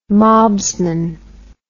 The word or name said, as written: mobsman